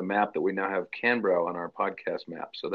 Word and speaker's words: Canberra: Yeah, Canberra.